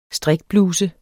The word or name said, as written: strikbluse